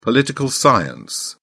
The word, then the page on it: political science: British English